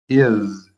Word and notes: IS: American English

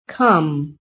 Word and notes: COME: American English

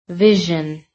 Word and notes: vision: American English

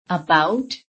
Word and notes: ABOUT: American English

